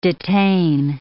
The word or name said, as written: detain